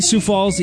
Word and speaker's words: Sioux Falls: The number five, the city of Sioux Falls was able to halt a movement to start pronouncing the X in Sioux.